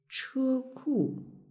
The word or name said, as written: cheku